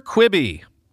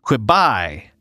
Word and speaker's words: Quibi: Also, I've learned that Quibi is short for Quick Bites, which introduces the horrifying notion that it's actually pronounced Quibi. But good luck with that, because if you look at the name, it clearly reads Quibi.